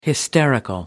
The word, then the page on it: hysterical: American English